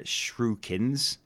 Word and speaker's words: shuriken: They're shurikens. I don't know how you pronounce that. Shuriken. It's a shuriken. – Yeah, that's correct.